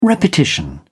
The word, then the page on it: repetition: British English